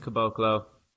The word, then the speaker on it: Caboclo: Greece had a chance, tied the game on a free throw in the final seconds, and Bruno Caboclo, How do you say that? – I think it's Caboclo.